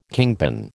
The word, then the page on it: kingpin: American English